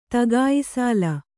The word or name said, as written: ತಗಾಯಿಸಾಲ